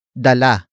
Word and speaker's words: dala: Dalhan comes from the root word dala.